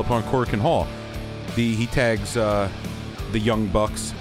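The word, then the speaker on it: Korakuen Hall: Say it properly, sir! Corken Hall! It's the first place I've ever wrestled in Japan. Corken Hall!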